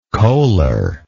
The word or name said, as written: coaler